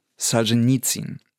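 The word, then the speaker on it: Solschenizyn: Naja, jedenfalls, lange Rede, kurzer Sinn, Solzhenitsyn, also die russische Aussprache ist ja Solzhenitsyn. So, und im Deutschen sagt man mal Solzhenitsyn.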